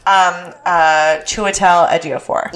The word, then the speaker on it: Chiwetel Ejiofor: And I cannot pronounce his name. Do you know how to pronounce it? – What's his? – It's like Chief Fawell. – Chiwetel Ejiofor. – Yes, Chiwetel Ejiofor.